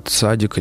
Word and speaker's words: цадик: На иврите мы говорим с ударением на последний слог, есть ударение на предпоследний слог, это основные варианты. А на идише ударение может быть где угодно, цадик тоже подойдет.